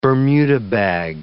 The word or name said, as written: Bermuda bag